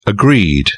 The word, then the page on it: agreed: British English